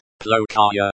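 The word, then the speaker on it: плохая: So, if we said plakhaya, pronounced plokaya, it would mean bad, but would have to be used with a feminine noun.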